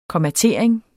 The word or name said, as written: kommatering